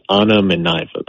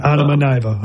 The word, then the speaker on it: Anna Mineva: about what she hoped to accomplish here by signing the Russian player named, it's pronounced Anna Mineva, by the okay. – way. – Okay, Anna Mineva.